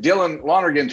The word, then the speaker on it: Lonergan: Welcome in Dylan Lonergan to the show.